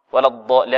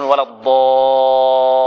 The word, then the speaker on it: ولا الضالين: ولا الضالين، هذه نبر، فيها نبر. قال يقول العلماء، علماء التجويد يقول النبر هنا فائدته أن تنطق بالحرف مشددة، لأنك إذا لم تنبر نطقت بالحرف مخففة. ولا الضالين، لأن بعض الطلبة يقول ولا الضالين كما قرأ الشيخ عبد المنعم، كما قرأت الآن، قرأت اللام مخففة.